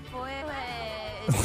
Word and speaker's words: foliage: Look at the fall foliage. – Yo, she she said it. Foliage. – Look at the fall foliage. She obviously said it right.